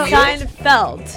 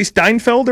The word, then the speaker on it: Seinfeld: Like no, Seinfeld.